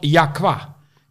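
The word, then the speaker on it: Y'a quoi: Uitgesproken als Jakwa.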